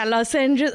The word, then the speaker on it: lozenges: mouth lodgings. – Mouth lodgings? Kya hota hai? Lozenges? Like? – Yeah, lozenges. Oh, how British of you. I made it the Indian pronunciation.